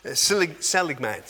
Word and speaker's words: Seligman: There's a guy by the name of Martin Seligman, that's how you pronounce it.